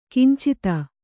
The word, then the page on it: ಕಿಂಚಿತ: kincita